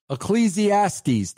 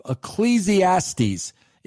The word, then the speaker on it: Ecclesiastes: Ecclesiastes, that's how you say it, I believe. Ecclesiastes. It took me the whole article, but I got it right.